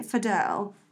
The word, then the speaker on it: Fadell: Tony Fadell, or Fadel. How would you say that? I think Fadel.